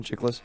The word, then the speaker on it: Chiklis: Is that how you pronounce his last name, Chiklis? – I'm pretty sure, yeah.